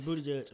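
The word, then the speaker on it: Buttigieg: It's not butt. It's Booty Judge. I'm trying to. It's Booty Judge. I refuse to say anything else.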